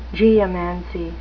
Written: geomancy

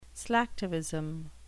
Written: slacktivism

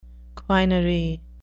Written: quinary

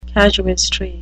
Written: casuistry

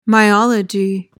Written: myology

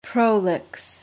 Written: prolix

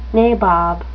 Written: nabob

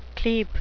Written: clepe

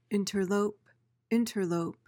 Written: interlope

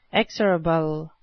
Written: exorable

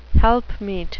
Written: helpmeet

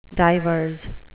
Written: divers

